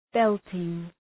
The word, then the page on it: belting: ‘beltıŋ